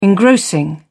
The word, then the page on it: engrossing: British English